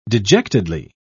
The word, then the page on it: dejectedly: British English